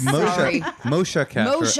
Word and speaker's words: Moshe Kasher: It's Moshe Kasher, as you've been pronouncing perfectly correctly this entire time.